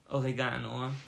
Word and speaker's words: Oregano: Oregano. Betonung auf dem A. Ja, definitiv Oregano. – Warum? – weil es ja auch Restaurant ist und nicht Restaurant.